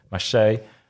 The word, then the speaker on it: Marseille: Marseille there? – Or oh, is that Marseille. Marseille. Marseille. – That's a very Norwegian pronunciation of Marseille.